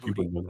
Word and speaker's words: Djibouti: Djibouti.